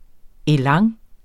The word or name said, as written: elan